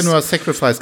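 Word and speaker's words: Senua's Sacrifice: gerade Sense, ich kann es nie aussprechen, Sensua Sacrifice. Senua Sacrifice, genau das ist der ja.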